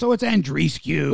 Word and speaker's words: Andreescu: So it's Andreescu.